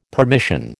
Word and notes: permission: American English

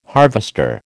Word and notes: harvester: American English

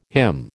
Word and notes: hymn: American English

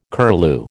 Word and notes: curlew: American English

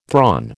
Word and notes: thrawn: American English

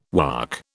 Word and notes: Waac: British English

